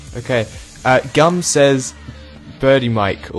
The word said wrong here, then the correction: Birdemic: Oh, Birdemic, yes.